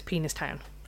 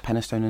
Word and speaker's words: Penistone: Because I've never heard it out loud, and I always read it as Penis Town. – I believe it's Penistone and Stockbridge, or at least that's how...